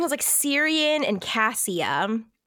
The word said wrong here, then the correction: Cyprian: I think that's Cyprian. How the narrator pronounced it.